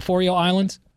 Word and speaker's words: Faroe Islands: Faroe Islands.